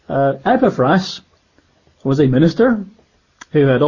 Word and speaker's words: Epaphras: As you also learned from Epaphras, I used to call him Epaphras, but I checked out the Greek, it should be pronounced Epaphras,